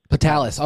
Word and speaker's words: Petalas: Petalas.